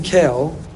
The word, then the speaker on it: Kel: So this name, Kael, Aleph Lamed, we pronounce Kael, it's one of the holy names of God that we cannot say casually or lightly.